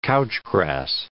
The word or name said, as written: couch grass